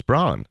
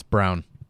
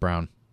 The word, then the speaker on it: Braun: It's Braun. It's Brown. It's Brown. It's pronounced Brown. It's Brown. – Are it, you sure? I thought it was Braun too. How can it not be Braun? – On draft night, they said Brown.